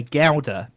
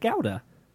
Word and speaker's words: Gouda: The one thing about cheese that I never really got is that we say Gouda here in the UK to pronounce that type of cheese. You're looking at me funny. You say Gouda, right? – I say Gouda. – But Americans say Gouda. I've always said Gouda. – I hear it's just Gouda here. – It's definitely Gouda in the US.